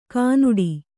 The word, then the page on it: ಗಿಣ್ಣಿಡು: kannada